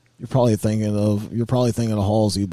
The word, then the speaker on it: Halsey: Are you saying her name wrong? – It's Halsey, bro.